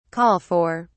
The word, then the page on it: call for: British English